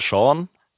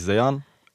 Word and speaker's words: Sean: Also im ersten Moment immer Sean, bis ich dann halt gesagt habe, okay, im Deutschen sagt man eigentlich Sean.